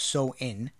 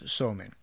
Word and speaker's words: Samhain: Now, the Samhain, S-A-M-H-A-I-N, is actually pronounced So-In, but it's spelled S-A-M-H-A-I-N, and it looks like you would pronounce it Samhain, but it's Somain.